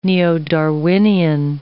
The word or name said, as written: neo-Darwinian